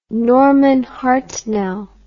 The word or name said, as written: Norman Hartnell